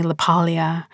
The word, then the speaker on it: LaPaglia: Anthony LaPaglia would not have appreciated me adding that hard G into his name.